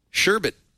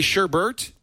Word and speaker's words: sherbet: But anyway, right at home, sherbet. What? You say sherbert? Who says sherbert? – The rest of the world. – No. Look how it's spelled. S-H-E-R-B-E-T.